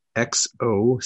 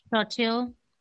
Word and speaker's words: Xochitl: Xochitl. – What is it? – Hold on. Xoch. – Itl. – Xochitl. No, Xochitl.